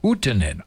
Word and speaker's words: Huttunen: It looks like Huttonen to me, H-U-T-T-U-N-E-N, but it's pronounced Huttonen, I'm told.